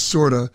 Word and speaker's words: Corda: It's spelled C-O-R-D-A, but pronounced with like an S, Sorda.